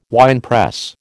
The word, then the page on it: winepress: American English